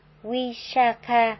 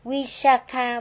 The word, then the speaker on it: Visakha: Nếu như người ta là một phụ nữ, tên họ sẽ được phát âm là Visakha với âm A dài. Còn nếu là người nam, tên họ được phát âm là Visakha với âm A ngắn.